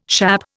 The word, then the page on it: schappe: Indian